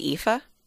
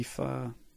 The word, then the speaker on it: Aoife: Are you thinking of your Aunt Sarah's friend, Aoife? – Oh, yeah. – Maybe Aoife. Yeah, anyway. Like, that's spelled crazy. – Yes. – A-O-I-F-E.